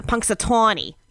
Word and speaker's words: Punxsutawney: Punxsutawney.